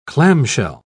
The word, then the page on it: clamshell: British English